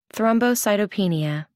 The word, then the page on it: thrombocytopenia: American English